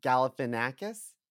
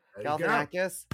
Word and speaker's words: Galifianakis: Well, in the campaign, Will Ferrell goes to punch Zach Galifianakis. Did I say it right? – Yes. – Galifianakis.